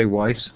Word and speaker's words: Weis: Okay, so am I saying it right, Allan, if I say Weis? – You are saying it right.